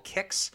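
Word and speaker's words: Qix: I also played a game called Kix. I think that's how you say it. It's Q-I-X on Game Boy.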